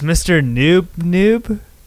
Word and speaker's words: Mr. Noob: He's Mr. Noob.